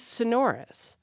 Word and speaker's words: sonorous: I went and looked at the usage note in the American Heritage Dictionary, and they note the historical pronunciation is sonorous, but the stress has bumped up to the first syllable to the extent that by the 1995 survey, 84% of the usage panel preferred the first syllable pronunciation.